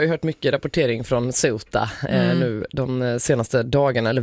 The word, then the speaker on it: Ceuta: Så att det blev Ceuta. Lite som Ceuta, alltså i och med att man kutar i Skåne typ.